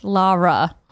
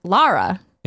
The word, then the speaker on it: Lara: Andrea, it is pronounced Lara. – There was a big Lara Croft thing. – I did a shtick thing about Lara Croft when I hosted a segment at E3 2015 in the Xbox booth, and I asked one of the members of the development team at Crystal Dynamics. I was like, set the record straight. – Yeah. – How do you say her name? And they said Lara.